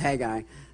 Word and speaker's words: Haggai: You might not know how to pronounce it. It's just Haggai.